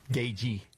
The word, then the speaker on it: Gaethje: Gagey. That's how you pronounce it. The TH is silent.